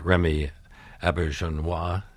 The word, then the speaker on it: Remy Auberjonois: Well, this play, which also, by the way, we should point out, has Remy Auberjonois, mm-hmm. is that how we pronounce mm-hmm. that name? – Yeah, exactly. Yeah.